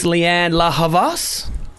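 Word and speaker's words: Lianne La Havas: Here's Leanne La Havas.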